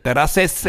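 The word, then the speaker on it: Terrasses: Also ich meine jetzt hier Terraces. Hast hast du gut gemacht, hast du gut gemacht. Wirklich gut ausgesprochen.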